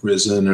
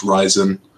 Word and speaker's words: Ryzen: Most people, when you look at it, you would expect it to be pronounced like Risen or something like that, but it's pronounced Ryzen.